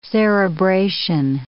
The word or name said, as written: cerebration